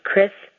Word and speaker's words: Cris: There is no H in my name. I have grown to loathe and detest the letter H because people often put an H in my name. My name is spelled C-R-I-S, four letters. My last name is Beam, B-E-A-M, as in Mary, four letters, as in Beam Me Up, Scotty, Chris Beam.